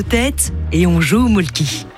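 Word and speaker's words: Mölkky: Inventé en Finlande en 1996, ce jeu de quilles s'écrit M-O-T-R-E-M-A-L-K-K-Y. – Ça s'écrit molki, mais ça se prononce molke. – Le molki. – Plutôt un E qu'un E, mais pas un U. Comme ça fait molque.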